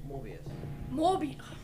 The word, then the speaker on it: Morbius: And there's also Mobius, which is another vampire. – Morbius. It's not Owen Wilson Mobius.